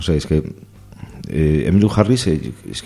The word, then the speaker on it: Emmylou Harris: También vemos aquí una de las pocas mujeres que actúan en este documental, que es Emmylou Harris.